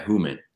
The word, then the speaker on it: Houman: Yeah, Houman.